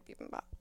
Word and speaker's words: bibimbap: Did you have a bean bean bop? – I did not, but my friend did. The place we go to has really good bibimbap.